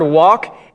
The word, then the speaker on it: walk: Look up the word walk, and you look at how it's pronounced. It says W-O-K. W-O with a little thing. K. So there's no L in that.